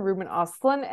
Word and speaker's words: Ruben Östlund: But she correctly said Ruben Östlund, and I was like, oh my God, this girl really did the most that she could do, and I really love it. But yes, Ruben Östlund, the nominations are yeah.